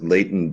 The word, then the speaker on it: Leighton: My name is Leighton Bellamy Unterweiner Gray. My first name is spelled L-E-I-G-H-T-O-N. Last name is G-R-E-Y, like the famous football cup.